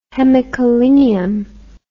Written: hemicholinium

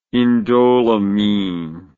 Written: indoleamine

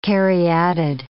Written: caryatid

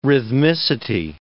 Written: rhythmicity

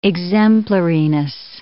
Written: exemplariness

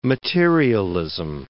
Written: materialism

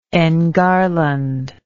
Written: engarland

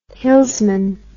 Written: hillsman

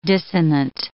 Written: dissonant

